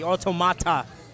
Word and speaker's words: Automata: Am I pronouncing that right? – That's very good. I like how you enunciate the T, Automata. – Basically, the pronunciation, though, is correct. – It's very European, yes.